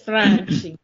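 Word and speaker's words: Franchi: It's Frankie. – Frankie. You gotta use your hands when you say it.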